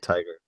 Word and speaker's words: Taika: uh Taika. How do you pronounce it? Tucker. Taka. Yeah, like Tiger. Tiger.